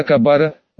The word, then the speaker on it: Кабара: Город Джита был 3-м сосредоточением сил на этой планете, и владела им семья Кабара, ударение на последний слог прим, афт.